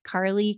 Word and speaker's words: Carly: So people can connect with me there at Carly Communicates, and my name is spelled C-A-R-L-Y.